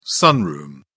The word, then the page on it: sunroom: British English